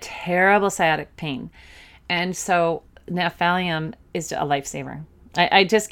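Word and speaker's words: Gnaphalium: Another really good remedy for helping with different pains and is Naphalium, and that is sciatica pain, and that's spelled G-N-A-P-H, okay?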